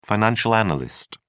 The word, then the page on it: financial analyst: British English